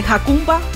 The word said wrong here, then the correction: Jacumba: Jacumba. – Sí, Jacumba Matata.